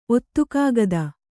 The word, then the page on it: ಒತ್ತುಕಾಗದ: Kannada